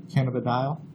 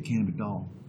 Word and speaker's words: cannabidiol: Cannabidiol. – You say cannabidiol? I guess you would know. I think I would say it cannabidol. Cannabidol. Cannabidiol? – I've always said it cannabidiol.